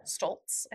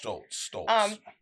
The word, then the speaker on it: Stoltz: Stoltz, I think that's how you say it. – Stoltz.